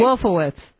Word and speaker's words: Wolfowitz: Wolfowitz.